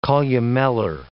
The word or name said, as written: columellar